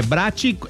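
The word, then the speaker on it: Bratti: É Brati, é B-R-A-T-T-I, tá bom?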